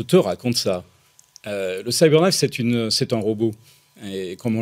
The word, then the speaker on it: CyberKnife: Et, la grande force, je crois, c'est donc ce robot qui s'appelle Cyberknife.